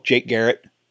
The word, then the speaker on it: Jake Garrett: On Twitter, Facebook Jake Garrett, just like it's spelled.